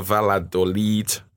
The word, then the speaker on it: Valladolid: Mina, Real Madrid's interim manager Solari, a 2-0 win over Valladolid.